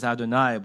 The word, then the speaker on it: Adonai: Whereas the word we pronounce as Adonai, but is spelled Yud-Heh-Vav-Heh, or Yud-Keh-Vav-Keh, depending on how you say it, seems to be the specific name or experience that we believe that our ancestors and we had with God.